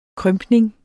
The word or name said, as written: krympning